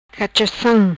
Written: Cachacam

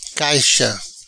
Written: Caixa

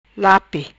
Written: Lape

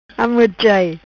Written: Amorchai

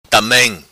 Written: Tamem

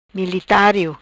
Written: Militario

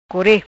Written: Core

